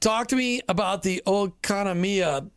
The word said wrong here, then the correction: Oikonomia: Network. I can't even do it. I tried to, give me Oikonomia. – The Greek.